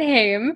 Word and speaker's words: Haim: Today, we have our special guest on, Lisa Haim. That's how we pronounce it, Haim.